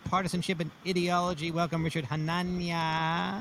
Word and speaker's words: Hanania: How do you say it? – I say Hanania. – Hanania. – But I defer to whatever people want to say.